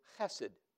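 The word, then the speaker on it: Chesed (חסד): The highest virtue in all of ancient Israel was a term that is pronounced in Hebrew, Chesed.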